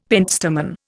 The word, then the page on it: pentstemon: Indian